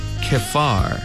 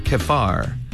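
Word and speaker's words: Chephar: 그 다음에 그발 암모니는 K로 시작해요. 케파르. 그발을 여기도 PH가 있어요. 케파르. 케파르 뒷부분은 암모나이. 암은 암몬처럼 생겼어요. 그 다음 아이만 붙어 있습니다. 암모나이. 다시 한번 케파르 암모나이.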